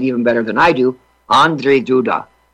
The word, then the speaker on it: Andrzej Duda: Over, and During the break, I was learning how to pronounce Andrzej Duda. Andrzej Duda. That's the pronunciation of the president of Poland. That was my best effort.